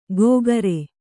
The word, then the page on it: ಗೋಗರೆ: gōgare